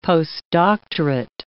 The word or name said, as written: postdoctorate